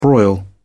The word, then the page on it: broil: British English